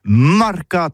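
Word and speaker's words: mercat: Eh bien, d'ici à toutis, le mercato, de l'Uni ou le mercato des rebelles, mais en gascogne toulousano, c'est prononcé les marcat avec un A en gascogne toulousaine,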